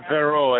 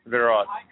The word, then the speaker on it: Verot: Yeah, Bishop Vero, as they call it, Vero, but it's spelled V-E-R-O-T, Verrott.